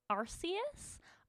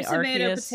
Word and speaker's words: Arceus: I think Arceus. It's like a. It's like a. It's like a. – Hard C. – It's like Arceus. – I've seen so many videos. Some people say Arceus, some people say Arceus.